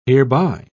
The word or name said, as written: hereby